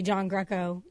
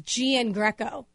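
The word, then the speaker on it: Giangreco: My entire life, and if you ever ask my family, it's G-N Greco. Like exactly how it looks, like G-N Greco. – Uh-huh. – But in Italy, G-I-A-N is John. Like the baseball player John Carlos, no one gets his name wrong. They don't call him G-N Carlos. They call him John Carlos.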